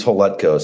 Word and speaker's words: Hletko: Last name is Holutko.